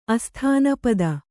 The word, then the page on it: ಅಸ್ಥಾನಪದ: Kannada